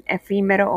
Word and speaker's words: efímero: Esta palabra es estrújula, la cual se acentúa en la penúltima sílaba y viene siendo la fi en efímero o efímera.